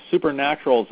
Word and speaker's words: Supernaturals: Supernaturals. There's a Z at the end of Supernaturals. Am I saying it right? Supernaturals? – Mm-hmm. Yeah.